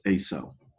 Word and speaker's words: AISEW: Our brain is performing a process that I call automatic, involuntary, subjective evidence weighing, AISEW, which I pronounce ASO.